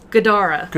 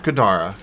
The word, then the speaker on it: Gadara: Kredara. – Gadara. – Gadara, yes.